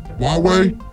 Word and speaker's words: Huawei: It's not how you say it though, Huawei.